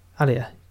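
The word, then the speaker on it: Alia: Aaliyah, did I say it right again? – You were close. – Aaliyah. Good.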